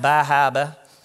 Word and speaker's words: Bar Harbor: It's actually pronounced Bahaba.